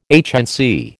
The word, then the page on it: HNC: American English